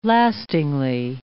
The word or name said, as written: lastingly